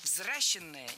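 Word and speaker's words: взращённое: Это должно быть внутреннее взращенное состояние. – Взращенное неверно. Исправляем ошибку. Заменяем «е» на «ё» во втором слоге и переносим ударение на нее. Взращенный, взращенное.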